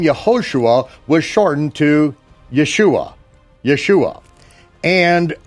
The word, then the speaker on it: Yeshua: So if you pronounce it Yahshua, as I do and as I did, and occasionally do, because I want it to be understood that that's the form of the name.